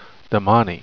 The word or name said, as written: damane